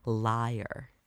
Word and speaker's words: lyre: And according to Google, it is pronounced lyre.